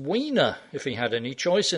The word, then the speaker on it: Weiner: I was reading the guy's name as Weiner. German E-I is pronounced I. If a guy's name is written S-T-E-I-N, we call him Stein, not Steen. Or if we don't, we should.